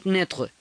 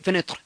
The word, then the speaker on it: fenêtre: O sea, se escribe fenetre eh, eh, con un sombrerito, una tilde de sombrerito, pero eso no se pronuncia todo completo, sino se pronuncia así como se come un pedacito, la eh, fenêtre, ¿sí? La fenêtre.